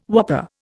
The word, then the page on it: whopper: Indian